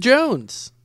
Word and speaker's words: Jonze: Talk no, it's pronounced as Jonze.